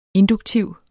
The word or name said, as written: induktiv